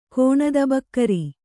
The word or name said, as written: ಕೋಣದಬಕ್ಕರಿ